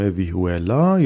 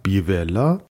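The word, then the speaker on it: vihuela: در اون زمان یکی از سازهای زهی زخمه ای قالب در ایبریا سازی بود به اسم ویهولا یا شاید به تلفظ دقیق تر بیولا که سایش روی گیتار افتاده بود و در ایبریا خواهان زیاد داشت و بسیار مورد توجه بود.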